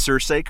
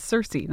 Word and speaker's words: Cersei: Did I at least pronounce Cersei correctly? – Nope. – Okay. Oh. How do you pronounce it? – It's just Cersei, not Cersei.